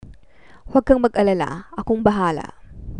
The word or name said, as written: huwag kang mag alala ako ang bahala